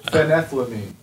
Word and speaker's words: phenethylamine: Phenylethylamine. – I think you're adding an L. – No, phenyl. – Phenethylamine.